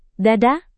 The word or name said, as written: dada